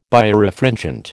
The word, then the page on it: Birefringent: American English